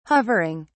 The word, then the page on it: hovering: British English